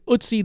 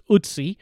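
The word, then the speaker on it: Ötzi: It's spelled O-T-Z-I, the Iceman. We looked up the pronunciation, and we think we're right on the way this is pronounced, Ootsie.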